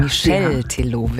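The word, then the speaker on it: Michel Teló: Ja, dann ist der Name des Interpreten Michael Telo oder Michael Telo. – Ja, ich glaube Michel Telo wird das auch. – Michel, ja.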